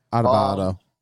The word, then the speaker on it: Adebayo: Adebayo.